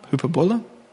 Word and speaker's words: Hyperbole: Das ist Hyperbole? Ja, so spricht man das aus.